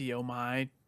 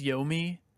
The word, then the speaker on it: Yomi: But also, it turns out I was pronouncing Trek to Yomi incorrectly. Evidently, it is Yomi. – Oh, you fucking made me look like a jackass. – I still think it's Yomi, though, but whatever.